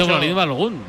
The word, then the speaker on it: Folarin Balogun: Dice Folarin Balogun. – Claramente. Tiene que ser Folarin Balogun, vamos.